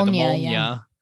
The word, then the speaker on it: Molnia: He goes under the guise of giving her the I don't want to mispronounce it. Molnia? Yeah, Molnia.